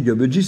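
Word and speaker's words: gabegie: En fait, ce c'est pas Gabji, moi j'ai toujours dit Gabji, c'est Gabji. Et les dictionnaires vous le disent, la Gabji, hein, c'est le grand désordre, euh, il faut prononcer j.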